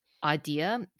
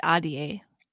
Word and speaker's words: Ahdie: I'm totally going to butcher the pronunciation of her last name, but Adia. A-H-D-I-E. – I always, Adia. In my head, I said Adia.